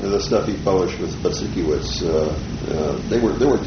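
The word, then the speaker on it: Budzikiewicz: Z-I-K-I-E-W-I-C-Z. Yes, Butzikiewicz.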